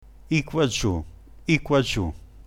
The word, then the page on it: iquagiu: dialetto